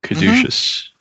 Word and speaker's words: Caduceus: He joined up with Caduceus. Is that how you pronounce it? – Caduceus. – Mm-hmm. Yeah. Caduceus.